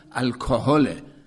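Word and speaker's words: alcohol: الان تلفظ انگلیسیش هم الکهله، الکهل اچش رو تلفظ میکنن به یه شکلی که همون یادآور کهل عربیه.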